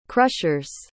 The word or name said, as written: crushers